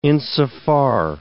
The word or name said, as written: insofar